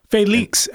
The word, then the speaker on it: Felix: Felix, actually. We're going to keep going. You're going to correct our pronunciation. – Yeah, it's actually great. – Felix. One thing while we're on Felix, I do want to say that. – Is that really how you pronounce it? – It really is.